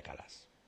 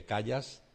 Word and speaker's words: Callas: se escribe María Callas, pero normalmente decimos María Calas, Si alguien lo dice Callas, no se ha equivocado.